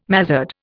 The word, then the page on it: mazard: Indian